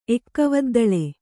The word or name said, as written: ಎಕ್ಕವದ್ದಳೆ